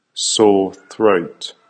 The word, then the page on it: sore throat: American English